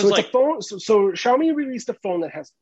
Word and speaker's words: Xiaomi: Is it Xiaomi? – No. It's I was on the tip of my tongue. – And then I fucked it up. – Yeah. Xiaomi. – Xiaomi.